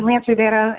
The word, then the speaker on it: Lance Rivera: Lance Riviera, and a lot of people call him Ooner.